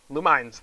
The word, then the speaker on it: Lumines: It's like Luminous, or Lumens. I don't know. Lumines. There we go. That's how you pronounce that.